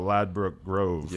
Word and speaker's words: Ladbroke Grove: And so that song we're talking about, Ladbroke Grove.